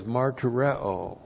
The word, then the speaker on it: martureo: Now in verse 7, that word to testify is martureo. M-A-R-T-U-R-E, long O. Martureo.